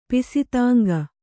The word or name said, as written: ಪಿಸಿತಾಂಗ